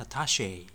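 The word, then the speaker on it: attaché: これはフランス語ですからね、アタッシェっていう感じの発音になります。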